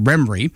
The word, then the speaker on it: Remrey: It is, it's pronounced Remory, and it's like it sounds, R-E-M-R-E-Y.